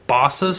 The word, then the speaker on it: Bossa: So the actual developer, which I believe, I don't know, I've never heard it pronounced, but I think it's Bossa Studios or Bosa, not like Joey Bosa, but with an extra S. – Like Bossa Nova.